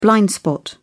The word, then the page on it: blind spot: British English